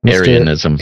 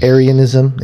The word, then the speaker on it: Arianism: And so here's what we do know is that during the Council of Nicaea, the work of Arius, where we get Arianism. Am I saying that right? – Arianism. – Arianism. Yeah, that's what I thought.